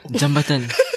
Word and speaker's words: jembatan: Jembatan. – Jembatan. – Bridge, right? – Huh? No. You're correct. But when you said jembatan, it sounded like jambantan.